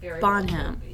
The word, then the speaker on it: Bonham: I think you're adding syllables. Bonham.